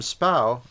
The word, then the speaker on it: spae: Now, the term spow, and that's the one pronunciation that I know of it, but I'm sure there are other ones from other places, spelled S-P-A-E, comes, I believe, from the same root as to spy or to see.